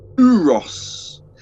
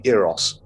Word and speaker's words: Euros: Uh, I believe the correct pronunciation for this is actually Eros.